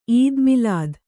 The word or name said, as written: ಈದ್ ಮಿಲಾದ್